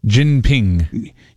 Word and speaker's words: Jinping: Is it Jinping? – Jinping. – Xi Jinping. Yeah.